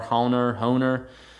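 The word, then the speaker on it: Hoehner: And is this book by Harold Honer, I guess is how it's pronounced. It's H-O-E-H-N-E-R, Honer.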